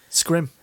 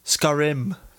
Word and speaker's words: Skyrim: Scrim. – Scrim Hudder Remix. – Let's call it the right name, because people think that's how we actually pronounce it. Skyrim.